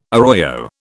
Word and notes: arroyo: British English

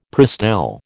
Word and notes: Prestel: American English

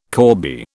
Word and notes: corbie: British English

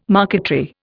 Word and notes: marquetry: Indian

